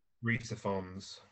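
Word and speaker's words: Rhys Ifans: I don't know how you pronounce his last name. – Reese Ifans.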